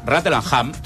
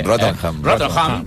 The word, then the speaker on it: Rattlesnake: El que està sonant ara, Desire, del 88, és de l'àlbum Rattlesnake, aquest ja ho començo a pronunciar bé, eh? – Rattlesnake, del 88, és el més americà de la banda.